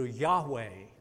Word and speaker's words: Yahweh: By the way, that is pronounced in Hebrew, Yahweh. That became in English because of translations, Jehovah. Some of our older Bibles have Jehovah.